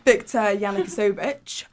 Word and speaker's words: Viktor Yanukovych: However, Viktor Yanukovych, that's how you pronounce his name, he was making closer ties with Russia.